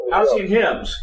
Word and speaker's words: Halcyon Hymns: How do you pronounce it? Is that Halcyon Hymns? – Halcyon Hymns.